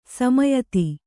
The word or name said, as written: ಸೌಮ್ಯತೆ